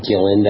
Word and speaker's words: Gillin: Yes, it's Peter Gillin. That's spelled G-I-L-L-I-N.